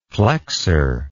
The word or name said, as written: plexor